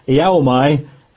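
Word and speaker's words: Iaomai (ἰάομαι): It's a Greek word. If you were writing it in English, it's a rather awkward word to pronounce. Try again. Iaomai. And if you're writing it down, if you're taking notes, it would be I-A-O-M for mother, A-I. I-A-O-M-A-I. Iaomai.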